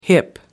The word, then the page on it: hip: American English